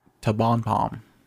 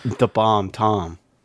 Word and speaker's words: TBomTom: It's the Bomb Tom. Did you say that? No. Okay, then don't say that.